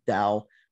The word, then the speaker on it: Tao: Because if I'm not mistaken, the word Tao means, or sometimes it's pronounced with a D, Dao, means the way.